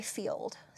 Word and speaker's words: Feeld: And another thing is, if you haven't already, try Field. So Field, yes, is an app, and it's called, it's spelled F-E-E-L-D.